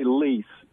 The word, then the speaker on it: Lise: Well, I don't think anyone knows actually for sure, but I always just say Lise. – Lise, L-I-S-E. – L-I-S-E.